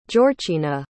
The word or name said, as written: jorcina